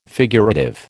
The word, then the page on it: figurative: American English